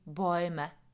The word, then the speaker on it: Bäume: Ještě mě napadá třeba strom, der Baum, die Bäume. Tam pozor na výslovnost, der Baum a U, Baum, ale jakmile to přehlasuju, dám dvě tečky nad A a koncovku E, tak už se to vyslovuje die Bäume.